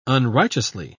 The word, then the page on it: unrighteously: British English